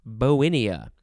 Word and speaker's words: Bauhinia: B-A-U-H-I-N-I-A. It looks like it's pronounced Bauhinia, but it is Bowinia.